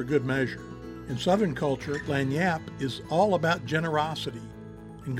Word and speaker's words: Lagniappe: Lagniappe, pronounced as Lagniappe, is a Creole term that means an extra or unexpected gift.